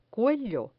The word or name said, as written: cuello